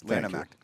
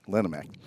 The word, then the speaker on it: Lanham: Tyler has sent a cease and desist letter through his attorney citing the Lanham Act. Did I pronounce that right? – Yes, Lanham Act. – Lanham Act.